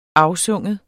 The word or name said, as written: AFSYNGER